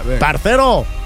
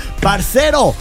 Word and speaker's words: parcero: Va, parcero. Parcero. Parcero. – Chique. A ver, no, es que el error es que estás pronunciando la C como lo hacen los españoles.